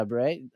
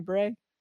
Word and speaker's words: beret: Yeah, beret. Is it a beret? Yeah, that's how you say it? – Yeah.